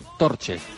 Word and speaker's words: Torje: La pronunciación correcta. Luego supongo que el propio jugador nos lo explicará. pero sí somos un poco precisos en cuanto a la pronunciación en rumano, algo así como torche.